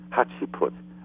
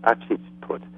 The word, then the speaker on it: Hatshepsut: Hatshepsut, I think it's pronounced, Hatshepsut.